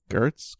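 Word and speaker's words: Giertz: Gertz? Gertz? – It's like, I think it's, yeah, but I think it's actually pronounced like Nietzsche.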